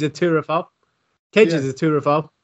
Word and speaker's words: turophile: And if you love cheese, you are actually called a turophile. – Oh. – Pardon me? – T-U-R-O. File, P-H-I-L-E. – Is it Turo or Truro? – Turo. Turophile. – Spell it again. – Turophile. T-U-R-O. P-H-I-L-E.